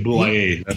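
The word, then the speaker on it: Bouillet: Bruce Bouye, is that how you say his name? – That's how I've always said Bouye.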